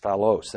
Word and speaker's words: phaulos: The word bad is the Greek word phalos. I'll spell it in the English transliteration. P-H-A-U-L-O-S, phalos. That means bad.